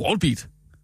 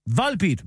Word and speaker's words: Volbeat: Det er det, det, er det wallbeat. Nej. Det hedder Wall Street. Hvis det var stadig på den det, måde. – Det er, en, gade. V-O-L siger vol. Volbeat.